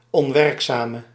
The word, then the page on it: onwerkzame: on·werk·za·me